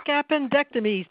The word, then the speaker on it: appendectomies: Book appendectomies to get the book inside of you out.